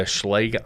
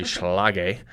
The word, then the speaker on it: Schlage: So we use the Schlage Encode, and I don't think I pronounced that right. I think it is actually Schlage, if I am pronouncing it correctly.